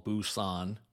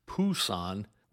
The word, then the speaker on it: Busan: By the way, back then, Westerners called Busan, which in English is spelled with a B as in Bravo, as Pusan with a P as in Papa.